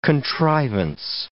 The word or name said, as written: contrivance